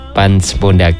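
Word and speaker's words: Pance Pondaag: Tulisannya gitu ya, sahabat Setara ya. Bacanya Pans Pondak.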